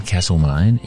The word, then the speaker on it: Castlemaine: Carefully pronounce that one. Castlemaine in Victoria.